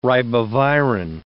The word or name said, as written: ribavirin